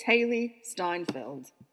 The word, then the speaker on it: Steinfeld: It's Hayley Steinfeld.